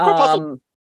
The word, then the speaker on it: corpuscles: Yeah, corpuscles. – Corpuscles? – Yeah, corpuscles. As in corpuscular.